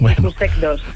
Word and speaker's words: IQSEC2: Es uno de los 19 niños, bueno, y familias afectadas en España por la mutación, espero de decirlo bien, del gen IQSEC2. Buenas tardes. – Hola, buenas tardes. – No sé si lo he dicho bien. – Lo has dicho perfectamente, IQSEC2.